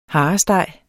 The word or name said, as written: haresteg